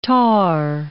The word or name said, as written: tar